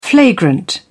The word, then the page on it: flagrant: British English